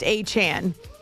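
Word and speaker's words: Achane: Devon, wait, I gotta practice, because I was saying A-Chain, because everybody else was saying A-Chain, but he has indicated that he prefers to say or to have it pronounced A-Chain.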